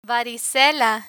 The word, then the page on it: varicela: Spanish